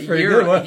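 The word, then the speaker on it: yirah: Yeah, like yira. Yira. – Yira. I'm not a Hebrew scholar. I had to listen to that several times to learn how to pronounce it.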